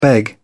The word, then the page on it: beg off: British English